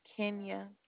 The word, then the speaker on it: Kenya: Did you say your name is Xenia? – No, it's Kenya. K-E-N-Y-A.